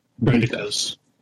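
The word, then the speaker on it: droidekas: It's you can see the entrance up here in between where the two droidecas. Is that what you said? – Droidecas.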